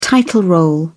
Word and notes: title role: British English

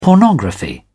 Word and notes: pornography: British English

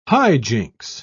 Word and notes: hijinks: British English

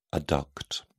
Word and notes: adduct: British English

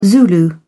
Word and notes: zulu: British English

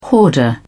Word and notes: hoarder: British English

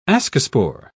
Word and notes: ascospore: British English

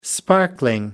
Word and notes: sparkling: American English

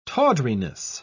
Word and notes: tawdriness: British English

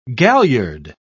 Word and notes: galliard: British English

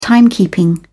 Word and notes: timekeeping: British English

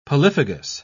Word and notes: polyphagous: British English

